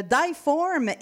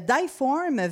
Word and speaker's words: Die Form: Puis euh, Die Form veut dire la forme en allemand, mais aussi prononcé en français joue sur l'homonyme difforme, effectivement, parce que si on le prononce de façon, euh, ben, en français, ça fait difforme.